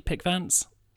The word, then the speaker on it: Pickvance: Did I say that correctly, Pickvance? – That's perfect.